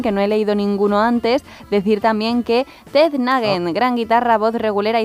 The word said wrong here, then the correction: Nugent: Pues es Ted, T-E-D y luego N-U-G-N-E-N-T. ¿No? N-U-G. – Nugent. – Nugent, sí.